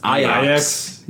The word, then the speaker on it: Ajax: I found out that Ajax is actually pronounced Ajax. – Yeah, yeah. – Yeah, it's, you know, it's Ajax. – They're it's Dutch. Ajax. They were shaved. No, we pronounce it Ajax, because that's my Dutch accent.